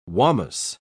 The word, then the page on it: wamus: British English